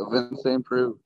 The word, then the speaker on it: Ovince St. Preux: Ovince St. Preux? – No, you got it. Yeah, you got it. Ovince St. Preux.